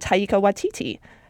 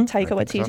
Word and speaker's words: Taika Waititi: and Taika Waititi. Am I pronouncing that right? – Mm-hmm. – Taika Waititi.